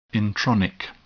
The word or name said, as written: intronic